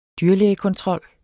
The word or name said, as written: dyrlægekontrol